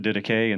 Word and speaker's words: Didache: Didache, it's been, some people pronounce it differently. I've heard it pronounced the Didache, and so that's the way I'm going to pronounce it.